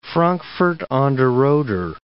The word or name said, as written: Frankfurt an der Oder